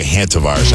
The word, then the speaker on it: Hantavirus: The first one, I've been pronouncing that wrong. It's actually a Hantavirus.